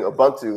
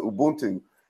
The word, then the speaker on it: Ubuntu: I was saying Ubuntu, and they were saying no, it's Ubuntu.